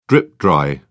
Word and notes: drip-dry: British English

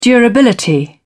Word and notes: durability: British English

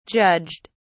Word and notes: judged: British English

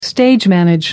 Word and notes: stage manage: American English

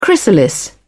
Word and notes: chrysalis: British English

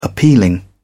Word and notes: appealing: British English

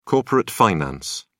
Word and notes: corporate finance: British English

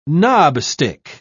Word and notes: knobstick: British English

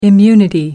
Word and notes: immunity: American English

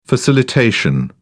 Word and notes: facilitation: British English

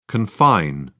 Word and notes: confine: British English

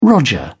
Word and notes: roger: British English

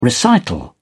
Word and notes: recital: British English

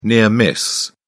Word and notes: near miss: British English

